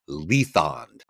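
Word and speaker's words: Lythande: This is Lathandi's story. Touched on this earlier about pronouncing names and whatnot. And it's my understanding that Marion Zimmer Bradley pronounced it Lethond. But,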